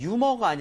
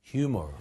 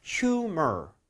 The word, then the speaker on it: humor: 그럼 유머가 아니라 어떻게 해야 돼요? – humor. – 그렇죠. humor. 이라고 하는 게 맞습니다.